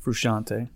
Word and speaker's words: Frusciante: Frusciante.